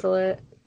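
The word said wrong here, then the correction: baliset: Yeah, and Balisset. I always say that wrong.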